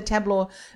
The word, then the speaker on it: Tableau: Tableau.